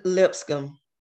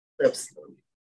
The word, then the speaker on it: Lipscomb: How do you pronounce his last name? I'm sorry. – Lipscomb. – Lipscomb.